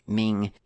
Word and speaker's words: MNG: for development which is fine next up is the lib Ming or MNG, really, but they say that it's pronounced Ming.